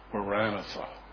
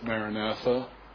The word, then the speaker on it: Maranatha: That little statement is very, very close in Greek to the Aramaic prayer, Maranatha. Now I know we pronounce it Maranatha, but in Aramaic it is two words, Marana Tha, and it means come, Lord.